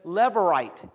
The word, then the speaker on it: levirate: It's called, I never can pronounce it correctly because I kept trying to use a different kind of word, but it's levirate, L-E-V-I-R-A-T-E, marriage.